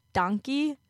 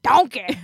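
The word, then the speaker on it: dankie: It's donkey. – Yeah, but I keep saying it like Shrek, like donkey.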